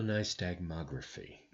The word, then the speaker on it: nystagmography: So let's start with VNG, which I have been told or consulted with as video stagmanometry, which is not right. – Oh my God, can I remember how to pronounce it? Video nystagmography is what VNG stands for, which is why we always call it VNG.